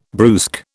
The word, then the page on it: brusque: British English